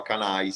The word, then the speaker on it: Kanye: Aiuto, no, no, no, no, si chiama, cioè, è tipo cani con la K, canai, si pronuncia.